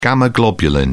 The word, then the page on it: gamma globulin: British English